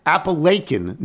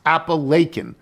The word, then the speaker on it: Apalachin: Today, as we are driving down 88 from Albany past Cooperstown, and as we are listening to the podcast, and you were talking about Apalachin, New York, which you did say incorrectly. We're actually headed to Apalachin, where my parents now live. It is pronounced Apalachin.